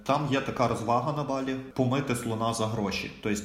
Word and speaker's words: Балі: Е, іменно правильно говорити балі, а не балі.